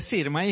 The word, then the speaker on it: Sirmai: Säg ditt efternamn också så jag uttalar rätt. Eh, Sirmai heter jag.